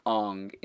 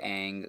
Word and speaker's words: Aang: I'm going to pronounce it like they do in China. Mm. And so it's Ong instead of Aang, which is throughout the entire cartoon.